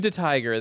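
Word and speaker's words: DeTiger: Blue De Tiger.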